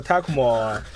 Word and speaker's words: Tecmo: 托库摩，对，这个它的发音是 Tecmo，所以大家一般就叫它托库摩。